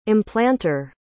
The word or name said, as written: implanter